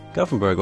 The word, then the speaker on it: Gothenburg: Gothenburg, or Göteborg is the Swedish pronunciation of it.